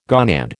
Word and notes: gonad: American English

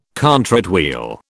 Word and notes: contrate wheel: British English

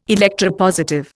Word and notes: electropositive: Indian

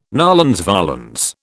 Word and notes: nolens volens: British English

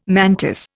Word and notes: mantis: Indian

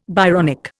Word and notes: Byronic: Indian

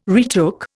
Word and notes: retook: Indian

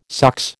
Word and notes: sox: American English

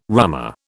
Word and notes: rummer: British English

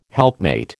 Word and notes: helpmate: American English

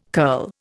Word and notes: curl: Indian

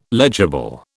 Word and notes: legible: British English

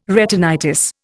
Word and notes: retinitis: Indian

